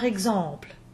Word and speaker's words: exemple: If initial EX is followed by a vowel or an H, which, remember, in French is silent, it will be a voiced GZ, like in the words, for example, par exemple.